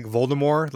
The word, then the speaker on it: Voldemort: Wait, is COVID-19 kind of like Voldemort? Like he who shall not be named? – Yeah, ooh, good pronunciation. – Like a superb owl. – I like that silent T. Very nice.